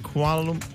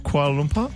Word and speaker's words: Kuala Lumpur: Is it Kuala Lumpur? – Yeah, you said it right. – Kuala Lumpur.